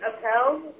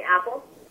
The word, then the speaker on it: Appel: My last name is Appel, but all of my friends call me Apple, and mine is Puyaba, F as in Frank, U-I-A, B as in Victor, A.